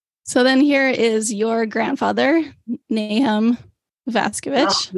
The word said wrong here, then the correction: Nahum: No, that's pronounced Nahum. – Nahum. Okay. – That middle consonant is one that doesn't exist in English, so many English speakers, but it's Nahum.